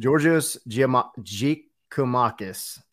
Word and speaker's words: Georgios Giakoumakis: Georgios Giakoumakis, I think, would be roughly how you say it properly.